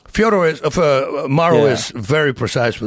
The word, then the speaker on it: Fyodor: We asked Fedor one time, what does your mother call you? He says, Fyodor. I said, we call you Fyodor. We did that on Pride. Everybody, you're butchering his name. His mom calls him like that.